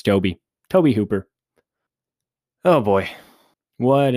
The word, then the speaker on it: Tobe: And now I was today years old when I found out it's actually pronounced Tobe Hooper and not Tobe. I had thought Tobe was a weird name, but spelled T-O-B-E.